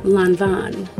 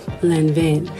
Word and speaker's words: Lanvin: Hint, it's pronounced Lanvin, not Lanvin.